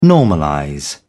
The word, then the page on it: normalize: British English